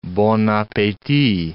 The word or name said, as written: bon appetit